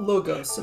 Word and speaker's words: Logos: So I was taught Logos, and I did political theory. – We're going to go with Logos.